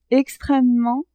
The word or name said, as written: extremement